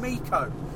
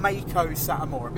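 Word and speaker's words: Meiko Satomura: He came out and he went, Fight Club Pro champion, Mako Satomura, because that is how it's said. That is the correct way to say it.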